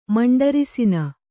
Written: ಮಂಡರಿಸಿನ

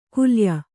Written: ಕುಲಾಯ

